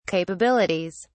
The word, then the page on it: capabilities: British English